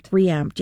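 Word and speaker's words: ReAmpd: Well, anyways, it's called Reamped. Reamped, R-E:AMPD. So Reamped, yeah.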